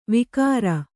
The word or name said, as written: ವಿಗ್ರ